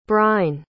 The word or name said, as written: brein